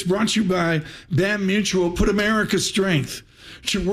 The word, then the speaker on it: BAM: Build a stronger tomorrow with BAM Mutual. Paul insists I spell it B-A-M.